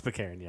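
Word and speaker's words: Baccarin: It's Pacaren, isn't it? Isn't it Pacaren? – It's Pacaren, yeah. TJ Miller's playing Weasel.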